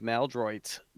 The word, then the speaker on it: Maladroit: So I wanted to go with Maladroits because I noticed we didn't have anything from Maladroits, and I love Maladroits. – Who doesn't? – Am I saying that right? – You are. It's Maladroit. Yes, correct.